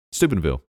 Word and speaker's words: Steubenville: I know how I would say it. S-T-E-U. B-E-N-V-I-L-L-E. – Oh, this is in Ohio because James, I believe, is from Ohio or lived in Ohio at some point, right? Steubenville. – Oh, that's how I would say it. Steubenville. – Yeah, I'm pretty sure that's right.